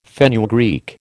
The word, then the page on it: fenugreek: American English